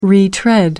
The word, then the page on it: retread: American English